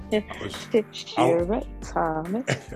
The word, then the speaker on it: Tishira Thomas: Why did you say my name like that? Hello, everybody. Tishira Thomas.